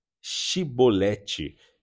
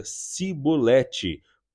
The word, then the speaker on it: Shibolete: Se o homem negasse, ele o mandavam dizer Shibolete. Se ele era de Efraim, dizia Sibolete, pois o povo de Efraim não consegue pronunciar essa palavra corretamente. Então, eles o capturavam e o matavam no lugar de travessia do Jordão.